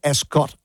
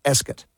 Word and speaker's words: Ascot: It's not Ascot either, it's Ascot is the way you pronounce it.